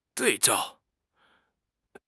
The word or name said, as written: 对照